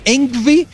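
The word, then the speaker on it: Yngwie: Primeiro porque o nome dele é Envy. Você tem que saber. Pronunciar que, em sueco. Polir o seu sueco, falar Engvi e é Engvi J.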